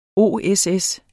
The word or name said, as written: OSS